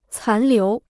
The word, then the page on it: 残留: cán liú